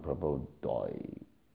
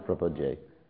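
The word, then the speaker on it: prapadye: So somehow or other, someone goes, Nishringamadim, Sharanam Prabodoy. Then you understand he's probably from Israel and trying his best to pronounce it. So, but in any case, yes, you're right. We should pronounce Prapadyoy. Nishringamadim, Sharanam Prapadyoy.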